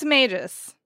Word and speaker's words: magus: I think it's magus. – Thank you. The queen's magus. – Or magus, possibly a hard G.